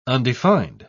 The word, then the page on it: undefined: British English